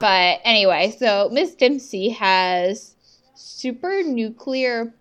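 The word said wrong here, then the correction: supranuclear: It's because, yeah, when I heard that, I was like, super nuclear? Like, that sounds kind of like superhero-y. – Yes. – It's actually supra nuclear, like S-U-P-R-A.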